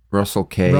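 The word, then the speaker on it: Russell Casse: I think it's K. – C-A-S-S-E. – Yeah. – Russell K. – Russell Case, then. – That's how you say it? – Russell Case.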